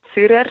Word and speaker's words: Zürer: It's called Zürer. It's very German.